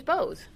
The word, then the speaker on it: Bowes: And my first guest of the entire week is James Bowes.